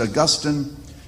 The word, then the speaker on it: Augustine: And the man that I'm referring to is Augustine, sometimes pronounced Augustine, who had a huge impact upon the Christian church.